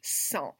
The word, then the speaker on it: sang: because like the word S-A-N-G, you would not expect that to be pronounced sans. Like, you don't hear the G at all.